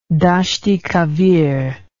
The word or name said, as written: Dasht i Kavir